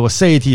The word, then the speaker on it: aseity: So aseity is the correct way to pronounce it.